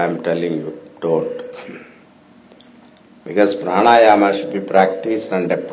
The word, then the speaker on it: pranayama: Then pranayama, breath control. You have to pronounce it properly, okay? Pranayama, breath control.